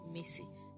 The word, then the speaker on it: Missy: My name is Bliss Akinyemi, but I prefer that you call me Missy. Missy is spelled M-I-C-I and pronounced M-I-S-S-Y. That's right. If you need a pen, grab one. M-I-S-S-Y.